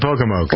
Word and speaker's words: Pocomoke: It's Pocomoke. – Pocomoke. – Come on. – You've been to Pocomoke. – What, have I? – Sure. – When was I in Pocomoke? – When you drive to the beach.